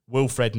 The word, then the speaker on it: Wilfried: On to the honourable mentions, and Leeds' Wilfried Gnonto. Sorry, I've completely said that wrong. Wilfred Gnonto.